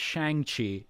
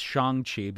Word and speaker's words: Shang-Chi: Now we know that it's not Shang Chi; it's Shang Chi because Lord oh, if Feige.